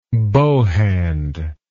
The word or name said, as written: bow hand